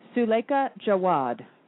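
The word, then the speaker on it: Suleika Jaouad: I know how to pronounce the first name, but her name is Suleika, S-U-L-E. It's Suleika... – Here, the pronunciation, actually, the New York Times has the correct pronunciation. – What is it? – Suleika Jawad. – Jawad. – Mm-hmm. – J-A-O-U-A-D.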